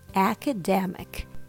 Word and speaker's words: academic: Remember the pronunciation, academic. Keep the first and third syllables strong and the other ones weak.